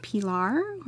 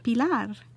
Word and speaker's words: Pilar: Because is it Pilar or is it Pilar? When I first came to Austin, I'd say, hi, I'm Pilar.